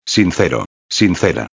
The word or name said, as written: sincero-a